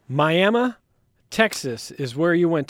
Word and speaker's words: Miami: Yeah, it's spelled Miami, pronounced Miami, and it's a small town in the panhandle of Texas.